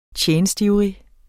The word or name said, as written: TJENSTIVRIGT